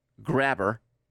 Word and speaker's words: Grabher: This guy named Lauren Grabher. It's spelled G-R-A-B-H-E-R.